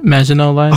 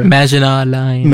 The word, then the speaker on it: Maginot Line: Maginot Line. – Okay. I'm not a fancy person. Over it. Maginot Line.